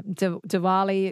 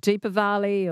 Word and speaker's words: Diwali: Yeah, Diwali, it's called, or it could be called Deepavali or Diwali. Yeah, it's got all sorts of pronunciations.